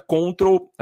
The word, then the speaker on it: CTRL: Eles adquiriram uma empresa chamada Control, na verdade, a abreviação, como se escreve, é CTRL, que é a abreviação do teclado.